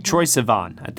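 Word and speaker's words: Troye Sivan: I hope I'm saying his name right. Sivan. Troy Sivan. I think that's how you say it. – It is.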